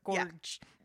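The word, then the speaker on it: Georg: But there was a real-life person, like an alchemist and astrologer in Germany named Johann George Faust, except for it's not pronounced George because it's German. It's like however they pronounce it, Gorge.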